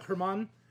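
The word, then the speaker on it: Germán: It could also be German Peralta or Paul Peralta. – Yeah, it's spelled German, but there's a... – Yeah, Herman is a typical name there.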